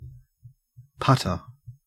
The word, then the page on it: putter: Australian English